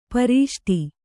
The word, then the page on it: ಪ್ರಷ್ಠ: kannada